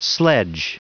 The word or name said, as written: sledge